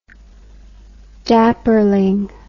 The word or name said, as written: dapperling